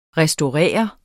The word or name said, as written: restaurere